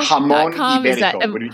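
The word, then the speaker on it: Jamón Ibérico: Is it Jamon Ibérico? – Jamon Ibérico. Jamon Ibérico, okay. Would be the Spanish pronunciation.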